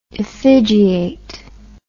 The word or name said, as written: effigiation